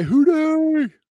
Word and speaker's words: Houdet: And it's a play on words of who are they? Houdet. – Yeah, exactly. – So that's honestly how I tell people to pronounce my name.